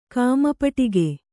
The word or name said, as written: ಕಾಮಪಟಿಗೆ